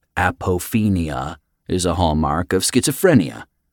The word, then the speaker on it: apophenia: Apophenia, Paul repeated, emphasizing each syllable.